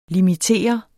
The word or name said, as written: limitere